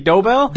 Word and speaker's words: Dobell: Yeah, it's actually Dobell. – Dobell. – You can say it however you want, as long as you say it deep and slow.